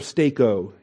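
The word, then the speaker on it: steko (στήκω): The present active imperative of steko is our imperative to stand firm. S-T-E-K-O. It's the long E and the long O. It's eta instead of epsilon, and it's omega instead of omicron.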